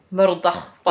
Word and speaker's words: Murdoch: It's probably Murdoch. – Like a loch. – Yeah. – Murdoch by yeah, loch. – That's exactly how it's pronounced.